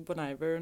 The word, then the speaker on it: Bon Iver: Ja, jag skulle ju varit på Bonnvier. Um, ehm, uttalar man det så? Det kanske man gör. Alltså, vissa säger Bon, bon Iver. – Några, bon Iver, ja. ja. och andra säger Bonnvier.